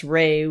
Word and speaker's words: Re: you know, I've always said Ra, but really I think it was pronounced Re, which makes sense just because of sun ray.